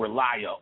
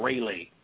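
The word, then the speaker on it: R'lyeh: black city called Relia or Rayleigh, sometimes pronounced, that is sunk underwater.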